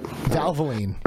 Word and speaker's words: Valvoline: Valvoline, is that right? – Valvoline, yeah.